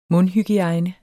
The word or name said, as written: mundhygiejne